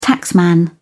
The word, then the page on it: taxman: British English